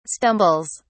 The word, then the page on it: stumbles: British English